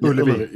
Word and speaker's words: Ullevi: And there's a live version from guys, help me out here with the Swedish pronunciation. There's a show on YouTube. It's like Uvoldi, Sweden. – Ullevi, yeah. – Ullevi.